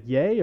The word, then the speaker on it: Ye: Is it like Ye or Ye? – It's Ye.